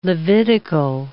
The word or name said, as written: Levitical